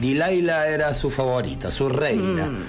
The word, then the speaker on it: Delilah: Sí, que se pronuncia Delilah.